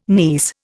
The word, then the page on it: niece: Indian